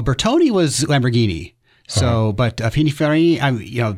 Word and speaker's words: Pininfarina: Pininfarina? – I've been saying that wrong for 50 years.